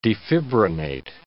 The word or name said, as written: defibrinate